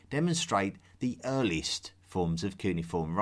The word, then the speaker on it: cuneiform: Cuneiform. – I call it cuneiform, but you will also hear it pronounced as cuneiform.